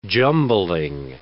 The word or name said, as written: jumbling